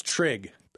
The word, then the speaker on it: Trig: No, that's Trig. – Oh. – Trig with an R. – Oh, Trig. – Maybe an R with a silent H and a J, but I think it's just T-R-I-G.